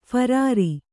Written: ಫರಾರಿ

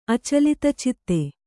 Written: ಅಚಲಿತಚಿತ್ತ

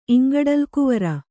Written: ಇಂಗಡಲಗುವರ

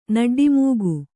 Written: ನಡ್ಡಿಮೂಗು

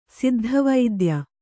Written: ಸಿದ್ಧವೈದ್ಯ

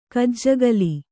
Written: ಕಜ್ಜಗಲಿ

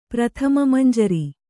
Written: ಪ್ರಥಮಮಂಜರಿ

